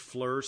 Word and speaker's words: FLIR: I believe it's pronounced FLIR Systems, F-L-I-R. That's also the symbol.